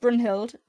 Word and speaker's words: Brunhild: It's still pronounced Brunhild, but it replaces the U with a Y, because in Danish a Y sounds like a U.